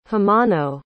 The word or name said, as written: hamano